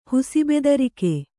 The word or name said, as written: ಹುಸಿಬೆದರಿಕೆ